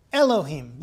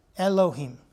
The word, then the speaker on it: Elohim: Elohim. That's how you pronounce it. Elohim. Him. Think of the H-E-E-M. Elohim.